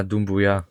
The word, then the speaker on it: Doumbouya: Ja, Doumbouya.